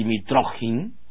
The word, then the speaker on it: Mitrokhin: Vasily Mitrokhin, o más, digamos, más correctamente pronunciado, Vasily Mitrokhin, ruso, fue un agente de la KGB durante muchísimos años.